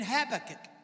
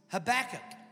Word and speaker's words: Habakkuk: It simply says, this word Selah occurs 74 times in Scripture, 71 times in Psalms, and 3 times in Habakkuk, I forgot how to say it now. The way I've always said it was Habakkuk. No, Habakkuk. The way I've always said it is Habakkuk. But apparently in the United States, it's Habakkuk.